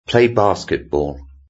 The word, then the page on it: play basketball: British English